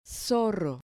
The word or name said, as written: zorro